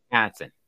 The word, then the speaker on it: Wisconsin: It's pronounced Wisconsin. – My wife is from Wisconsin. She does not say it like that.